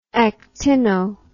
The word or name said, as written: actino